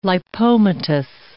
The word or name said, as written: lipomatous